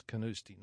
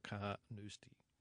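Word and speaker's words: Carnoustie: When I went to Canoosti, I asked some of the locals how to pronounce it, and they tell me it's Canoosti, not Carnoosti.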